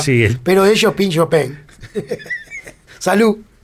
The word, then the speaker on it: Chauvin: Castellanizar un apellido francés. – En vez de Chauvin, es Chauvin.